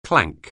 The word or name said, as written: clank